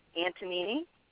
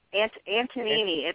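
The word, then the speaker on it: Antognini: My name is Linda Antonini. – Linda Antonini. – Antonini, it's an Italian. I can spell it for you. It's A-N-T. – It is pronounced Antony. – No, it's A-N-T-O-G-N-I-N-I. Tonini.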